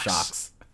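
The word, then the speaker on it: Sjokz: Best esports host, Sajokes. – I think it's pronounced Socks. Shocks. – Oh, Socks. Shocks. – Shocks. – Anyways, definitely Sock. Definitely Shocks. – It's S-J-O-K-Z for those at home.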